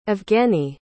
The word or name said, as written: evgeniy